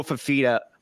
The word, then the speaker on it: Fifita: First of all, you start out the game referring to Noah Fifita multiple times as Fufuda. Come on, dude. It doesn't even look like that.